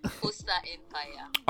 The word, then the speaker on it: Bosa Empire: Ladies and gentlemen, my name is Dayo Buttons and this is Pos Empire or Pos Empire. You should check her out and she sells one of the Posa Empire. – All right, so it is spelled You want to spell it or I should spell it? – B O S A E M P I R E.